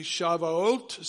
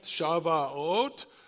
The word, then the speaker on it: Shavuot: Shavuot. Say it. Shavuot.